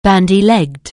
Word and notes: bandy-legged: anglais

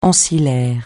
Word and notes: ancillaire: francais

